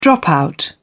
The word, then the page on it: dropout: British English